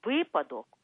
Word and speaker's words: випадок: пам'ятайте, будь ласка, що слово «випадок» у літературній мові завжди мало і має тільки один наголос на першому складі.